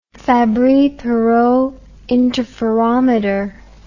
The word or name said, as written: fabry perot interferometer